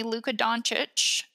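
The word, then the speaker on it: Luka Dončić: recently, Luka Doncic.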